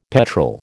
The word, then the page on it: petrol: American English